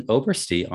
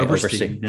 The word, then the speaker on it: Obersty: I am very excited to invite Justin Obersty on the podcast to talk about mirroring identity and sense of self.